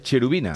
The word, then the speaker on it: Cherubina: en Querubina Oficial. Ahí ponemos eh, vídeos sobre que es se, pronuncia querubina, pero es cherubina.